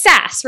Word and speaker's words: SaaS: Okay, you first, it's SaaS, right? Like as in like sassy? Okay. – Yes, it's one of those sassy, things I've only ever read and have never heard pronounced.